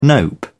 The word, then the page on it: nope: British English